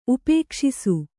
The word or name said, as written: ಉಪೇಕ್ಷಿಸು